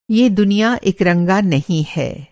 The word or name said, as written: इकरंगा